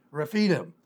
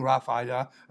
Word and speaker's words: Rephidim: I had a church in New Mexico, and we called that church Rephidim. We had to finally change it because nobody could say it. They go, Rephidim, Raphida. They couldn't pronounce it.